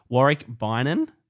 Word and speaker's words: Warick Beynon: And I'm joined here today by a very special guest, Warick Beynon. That's how you say it? Yeah, that's it. – That's the correct pronunciation.